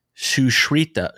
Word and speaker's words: Sushruta: It's Sushruta, Sushruta, Sushruta, Sushruta, Sushruta.